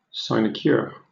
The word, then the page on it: sinecure: British English